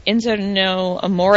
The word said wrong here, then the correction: Enzo Amore: Enzo Amore and Big Cass.